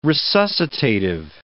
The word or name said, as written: resuscitative